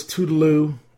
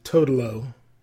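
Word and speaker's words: Toodle-Oo: the East St. Louis Tootaloo, or as Ellington pronounced it, Totolo, Lots of Fingers, and then Black and Tan Fantasy.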